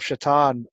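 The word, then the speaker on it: Šatan: Well, just the fact that his name is Rat, it reminds me of when the Boston Bruins had Miroslav Shatan on the team, and his name is spelled like Satan, S-A-T-A-N, you know.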